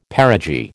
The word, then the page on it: perigee: American English